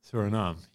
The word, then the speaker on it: Suriname: Is it Brazil, Argentina, Guyana, or, I'm really sorry if I've butchered this, is it Suriname? – Suriname, yeah.